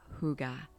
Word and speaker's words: hygge: You are going to be exploring the rather trendy and popular concept of the Danish tradition of hygge. And I did practice pronouncing that word, hygge.